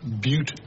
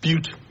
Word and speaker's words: Butte: Actually, it's pronounced Butte Juice with a long U, named after the town it was produced in, Butte, Montana. – Looks like butt juice to me. – And you're making my point for me. Butte Juice was almost a failure, because outside Montana, people pronounced it wrong.